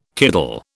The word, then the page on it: kiddle: British English